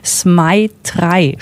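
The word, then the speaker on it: ZMEI3: Zmaitrai spricht sich das, schreibt sich Z-M-E-I und dann die 3 als Zahl.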